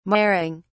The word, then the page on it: marring: British English